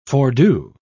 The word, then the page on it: fordo: British English